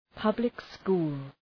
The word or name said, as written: public school